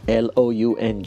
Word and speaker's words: lounge: L O U N